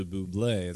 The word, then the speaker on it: Bublé: Once he changed the pronunciation from Bubble to Bublé, it really took off for him.